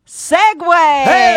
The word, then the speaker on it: segue: Okay, it's spelled S-E-G-U-E. – Uh-huh. – The mispronunciation is... – Segu? – Seg. – Uh-huh. – Versus... Segway.